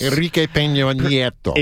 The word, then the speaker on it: Peña Nieto: Enrique Peña Nieto. – Exactly like that, with an emphasis on the Nia in Peña.